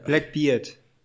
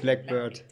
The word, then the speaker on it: Blackbeard: Äh, Blackbeard. Genau. Ja, ja und. – Nicht Blackbird. – Der Pirat.